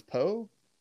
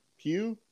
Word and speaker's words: Pugh: Yeah, it's Florence and then P-U-G-H, Florence Poe? Pew? – Pew, probably, yeah, okay.